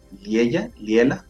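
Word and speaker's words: Liella: Y las chicas del grupo Liela, ¿cómo se pronuncia? ¿Liela? Se me va ahí la pronunciación del grupo. – Sí, así.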